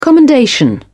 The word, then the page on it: commendation: British English